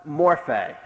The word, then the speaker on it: morphe: The one was morphe, M-O-R-P-H-E, long E.